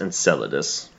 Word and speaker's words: Enceladus: It's pronounced Enceladus. – Enceladus. There it is.